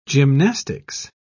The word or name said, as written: gymnastics